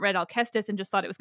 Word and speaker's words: Alcestis: So one, just to point of clarification, for my listeners, I'm going to say Alcestis because I always have, but I recognize that Alcestis is also a valid pronunciation in English.